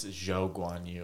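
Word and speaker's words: Zhou Guanyu: I mean, Guan Yu Zhu did really well, actually. Did I say his name right? I think I did. Guan Yu Zhu. – I think it's Zhou Guan Yu.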